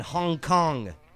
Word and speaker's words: Hong Kong: The Agricultural Fisheries and Conservation Department in Hong Kong, which rhymes with ping pong and ding dong.